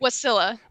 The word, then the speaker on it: Wasilla: Wasilla.